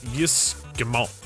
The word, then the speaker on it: Yves Guillemot: It's Yves Guillemot.